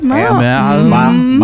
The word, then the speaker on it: Maoam: So the fun, part is, let's try and pronounce the name of this brand. Which is spelled M-O-A-M. – M-M-A. – It's a palindrome. – M-A-O-A-M. – M-A-O-A-M. I'm sorry, yes. – That's okay. – Maum. – Yeah, right. Maum.